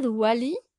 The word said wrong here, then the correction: Diwali: Y vaya que tengo mis notas y si estaba bien escritura era Diwali.